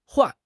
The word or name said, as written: huan4